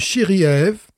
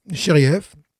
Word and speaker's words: Shiryaev: Ah, Mais voilà, donc le nom, c'est Denis euh, Chiriev. Chiriev. ça s'écrit S-H-I-R-Y-A-E-V.